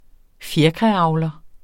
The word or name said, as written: fjerkræavler